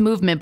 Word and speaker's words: MVMT: It's pronounced Movement, but it's spelled MVMT.